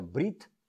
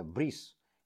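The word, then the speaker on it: Брит (ברית): На иврите обрезание – это брит мила. Слово «обрезание» по-русски происходит слово «обрезать». Брит, хоть в ашкенадском произношении это брис.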